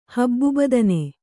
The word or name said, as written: ಹಬ್ಬುಬದನೆ